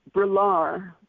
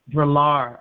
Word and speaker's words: Brillar: but I think her name is Brelar. – Brelar. – Brelar, all right.